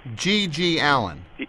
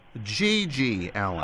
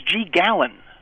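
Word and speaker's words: GG Allin: G.G. Allen. – How do you say it? – G.G. Allen. – Oh, I thought it was G. Gallen. – No, it's G.G. Allen.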